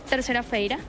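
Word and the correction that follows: terça-feira: Terza. Terza. – Terza, feira. Bueno, terza feira, sí.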